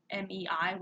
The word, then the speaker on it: Mei: The main character's name, which I don't remember and I didn't write down, means the month of May in Japanese, and her little sister is named May, Mei, which is pronounced like the month May, and the Sayama incident happened in May.